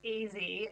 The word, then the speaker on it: Ezzy: One of the other stories that was quite delightful was how you highlighted Is it Ezzy? Is that how you pronounce it? Ezzy Moses' legendary spice shop in Bondi. – So it's pronounced Ezzy, as in it's short for Ezekiel.